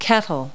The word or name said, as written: kettle